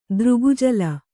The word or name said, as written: ದೃಗುಜಲ